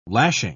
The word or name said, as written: lashing